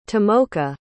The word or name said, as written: tomoka